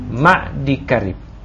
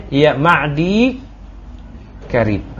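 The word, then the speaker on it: ma'di karib (معديكرب): Mereka itu keliru dalam pengucapan ma'di karib. Liltisaqil ya bil kaf. Ya. Karena menempelkan ya dengan huruf kaf. Fayazidu ya'an wa yakul ma'di karib. Ya. Padahal cuman ma'di karib. Akhirnya mereka bacanya ya ma'di karib.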